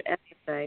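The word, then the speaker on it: Ephphatha: I know it looks really complicated to pronounce whenever you see it. But it's Ephatha, and Ephatha, Jesus actually said that in the Bible, and it means to be opened or to open yourself.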